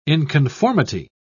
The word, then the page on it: inconformity: British English